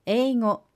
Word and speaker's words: 英語: The word for English language is 英語.